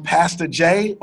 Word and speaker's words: Pastah J: So if you want to connect with me online, I'm Pastor Jay on Instagram and Twitter. Pastor Jay is spelled P-A-S-T-A-H-J. So think of it like pasta you eat, H-J, that'll help you out.